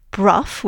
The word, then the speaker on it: Brough: Yes, so Lady Anne Clifford is connected with two of English Heritage's most spectacular ruined castles, Brough, which is spelt B-R-O-U-G-H, and Broom, which is spelt B-R-O-U-G-H-A-M, and they're both in Cumbria, and they're both very much well worth a visit.